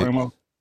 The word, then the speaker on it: Primo: Just to finish off with the NBA topics, Josh Primo, am I saying his name right? – Yeah, Flashing Dick. Primo.